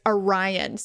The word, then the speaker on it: Arian: And when we did the spec spots for his commercials, I had to tell the guy, I'm like, look, Charlie, this guy, I know what it looks like, but it's Orion. So let's make sure we don't. And you can hear in the commercial how hard they're trying to say Orion because it's spelled Arian.